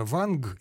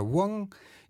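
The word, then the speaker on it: Wang: Если так, тогда все понятно, потому что это пишется-то ванг, если читать просто по буквам, как латинские буквы, а произносится-то вонг, и, соответственно, когда мы ищем способ передать эту фамилию средствами русской графики, перед нами в нашем распоряжении два способа.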